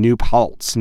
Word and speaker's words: New Paltz: New Paltz, New York is correct.